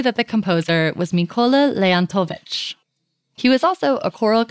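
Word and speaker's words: Leontovych: Leontovich.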